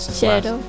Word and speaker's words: Cero: Now, his name is spelled like it should be pronounced cello, but his cero. – Cero. – Cero. – Cero. – Mashima Cero. – And it's not a long E, it's just like Cero.